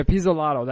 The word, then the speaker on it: Pizzolatto: Is that how you say his name? – Pizzolatto. You're the Italian. – Yeah, Pizzolatto. That's it.